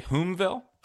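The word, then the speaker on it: Hulmeville: Today's show is sponsored by Miller Lite and the Homaville Inn. I think I'm saying that right. Homaville, H-U-L-M-E-ville. Homaville, right? – I said maybe Homaville. – Homaville. I think you're right. I think it's the Homaville Inn.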